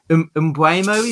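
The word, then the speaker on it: Mbeumo: And when looking at Brentford, I'm going to butcher this fucker's name. Mbuemo, is it? – Yeah.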